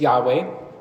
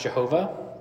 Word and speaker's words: Yahweh: So when you see that, Lord, all capitals, think it's Yahweh. That's his name. And I also want to mention that you may have heard it pronounced Jehovah or Yehovah. And those are just English transliterations of this Hebrew.